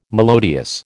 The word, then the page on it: melodious: American English